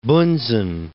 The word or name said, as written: Bunsen